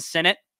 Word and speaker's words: Sinnott: Ben Sinnott, said it right that time.